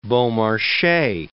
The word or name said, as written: Beaumarchais